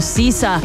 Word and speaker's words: SZA: Ella ya vino, Sisa.